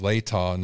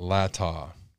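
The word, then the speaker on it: Latah: Lata County is pronounced Lata, not Lata.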